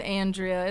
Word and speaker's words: Andrea: It is Andrea. It's the Midwestern flat A in every way you can have it.